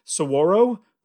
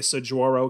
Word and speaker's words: saguaro: You know, I think it's pronounced saguaro, but I always make fun of it and say saguaro because that's literally how it's spelled.